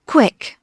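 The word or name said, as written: quick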